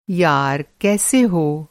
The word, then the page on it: यार: یار